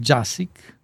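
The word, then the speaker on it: Jaksić: La de Iván Jásik, se escribe Jaksik, y la de Pedro Cunil Grau.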